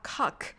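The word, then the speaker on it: Koch: コッホの発音は英語ではカックとなる可能性が非常に高いかと思います。なぜなら、バッハがバックと発音されるからです。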